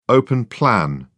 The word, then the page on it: open-plan: British English